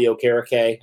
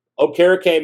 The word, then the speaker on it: Okereke: Another guy we got to talk about is Bobby Okereke. I did say it right this time. I know I messed it up on the live stream, but Okereke, man, I mean, you know, it was interesting.